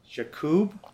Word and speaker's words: Yaqub: My guest is a hilarious comedian I met while I was here doing shows, Mo Yaqub? – Yep.